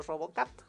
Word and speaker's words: RoboCup: RoboCup, eh, como Robocop, pero RoboCup, Cup, se escribe, 2022 en Tailandia.